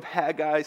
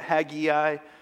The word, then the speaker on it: Haggai: It's on the Old Testament book of Haggai. Some pronounce it Haggai. Both are acceptable. You may even hear me say both of them.